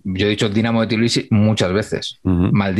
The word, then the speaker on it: Tbilisi: La, T primera no la, no la pronuncian, porque luego habló del equipo que había, que había para nosotros en Tbilisi. Sí. Y, él dice Bilisi.